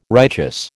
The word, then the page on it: righteous: American English